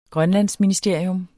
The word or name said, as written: grønlandsministerium